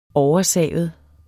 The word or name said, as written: oversavet